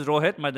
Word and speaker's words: Rohit: Is it Rohit or Rohit? – My name is Rohit.